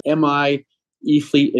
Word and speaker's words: MI-eFleet: So you've got the MI-eFleet EV Summit coming up here pretty soon. Did I say that right? – You did. It's MI-eFleet, and E stands for EVs, and is coming up on September 8th.